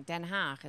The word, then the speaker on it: Den Haag: It's because it's pronounced wrong. Den Haag is how you actually pronounce it.